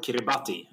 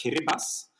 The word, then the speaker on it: Kiribati: Yeah, 34, and it looks to be called Kiribati, but it's pronounced Kiribas.